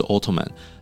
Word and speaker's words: Ottoman: 其实它讲的是同一个帝国，一个是用英文的发音，就是 Ottoman。